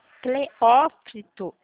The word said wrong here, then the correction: Teófilo Otoni: Cleófito. Nossa, gente, não consigo pronunciar. – Teófilo Otoni. – Preófito, não.